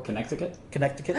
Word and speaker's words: Connecticut: Connecticut? – Yes. I pronounce the C.